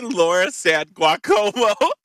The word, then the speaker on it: Laura San Giacomo: No, gerbil extraordinaire, Richard Gere, Erin Brockovich herself, Julia Roberts, Seinfeld and Shallow Hal best buddy, Jason Alexander, and Laura San Giacomo, if I'm probably pronouncing that really wrong. – I think it's Laura San Giacomo. – It's definitely Laura San Giacomo.